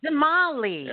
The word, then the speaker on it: Damali: to come back just so we can say Denali. – Denali or Damali? – Damali. – Oh, Damali. – I'm saying it wrong. Damali. – Yeah, like the truck.